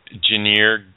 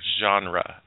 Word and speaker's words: genre: Is that how that's pronounced? Genre. This is hilarious. I don't even know. Genir, you know. – Genre. – I think it's genre.